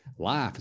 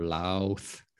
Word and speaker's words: laugh: So a lot of people say lau, lau, or lauk, or lauth, or something. It is laugh. So this is the sound that you want to make. It's an F sound, L. A, F, laugh.